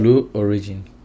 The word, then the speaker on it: Blue Origin: Eh, vayamos con el maestro de inglés porque nos diga cómo se dice. Blue Origin.